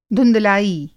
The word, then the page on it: धुँधलाई: Hindi